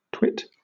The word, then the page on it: twit: British English